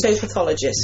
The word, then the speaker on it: phytopathologist: Phytopathologist.